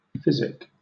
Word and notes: physic: British English

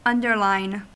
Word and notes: underline: American English

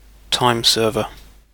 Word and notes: timeserver: British English

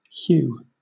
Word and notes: hew: British English

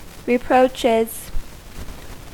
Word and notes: reproaches: American English

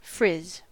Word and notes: frizz: American English

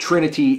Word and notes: trinity: American English

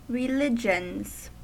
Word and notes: religions: American English

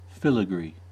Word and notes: filigree: American English

